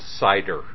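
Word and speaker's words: CIDR: Oh, and it's pronounced cider, for what it's worth.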